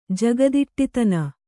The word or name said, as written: ಜಗದಿಟ್ಟಿತನ